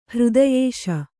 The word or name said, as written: ಹೃದಯಿಸು